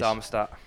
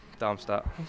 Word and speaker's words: Darmstadt: Darmstadt. Darmstadt.